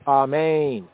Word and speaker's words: Amen: In the English, it's pronounced as an A, but in the Hebrew, Sephardic, as an ah. The Ashkenazi, the Eastern European Gomorite Yehudi, they pronounce it as an aw, aw, Amen, aw. The accent in both cases is on the second syllable, Amen, Amen.